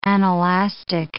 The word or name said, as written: anelastic